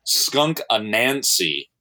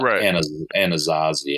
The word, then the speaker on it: Skunk Anansi: That band, I didn't pronounce them correctly. The one you were referring to, Patrick, with the lady with the face paint, it's Skunk Anansi. That's what I meant. I said Anazazi.